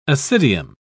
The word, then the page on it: ascidium: British English